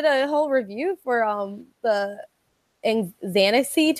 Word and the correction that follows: Xanarchy: tour, however you pronounce it for Lil Xan, Yeah, Xanarchy.